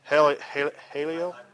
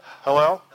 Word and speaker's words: Hallel: Haleel. – Hallel.